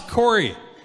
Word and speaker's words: Corey: And I'm like, it's not Gord. It's Corey. It's not even the same word.